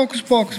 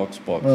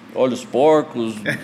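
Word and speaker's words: Hocus Pocus: E pagamos um preço por isso, que até hoje muita gente não sabe pronunciar o nome da loja, liga olhos porcos. olhos porcos.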